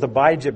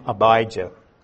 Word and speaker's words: Abijah: I heard it Abijah, Abijah, and Abijah. But I'm going to stick with Abijah because, if I'm not mistaken, that's the way Chester pronounced it. And he knew more about the Bible than any other man I ever knew, so I'll go with him. So we'll call him Abijah.